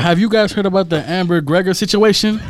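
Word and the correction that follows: Amber Guyger: Hey, did I even say it right or not? – It's Amber Geiger.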